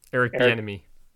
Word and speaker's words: Bieniemy: Eric Bieniemy.